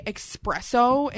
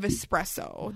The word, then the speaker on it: espresso: People here say espresso instead of espresso. There's an S, not an X. And that's just, like, literally how the word is spelt.